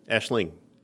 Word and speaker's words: Aisling: Aisling. – With a G, sort of.